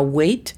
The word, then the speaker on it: weigh: Y pesar es to weigh, sin la T. La pronunciación fonética de weight es U-E-I-T. – Weight. – Y para poder decir pesar, añadimos to, que es T-U, y weigh se convierte a U-E-I.